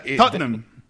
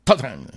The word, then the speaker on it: Tottenham: Tottenham. – Tottenham. – Son dos sílabas. – Ajá. Es difícil, ¿no? – Nosotros los británicos. Pero... – ¿Solo dos sílabas? – Sí, Tottenham.